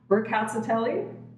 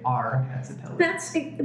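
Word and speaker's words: Rkatsiteli: And I would like your feedback on this because I've done a lot of research and it's either pronounced Rucazzatelli or Cazzatelli, depending on whom I speak to in terms of some people drop the R. – We usually keep the R and we actually accentuate it. Like we usually say R Cazzatelli.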